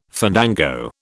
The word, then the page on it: fandango: British English